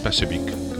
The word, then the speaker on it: Spasojević: Este Third View, lo nuevo de Juroš Spasojević. Él lo pronuncia mucho mejor que yo su apellido, lógicamente. Aunque se escribe Spasojević, es Spasojević, como dice él.